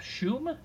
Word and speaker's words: Schum: Jacob Schum, is that what we said his last name is? – Yes. – Correct pronunciation on last name.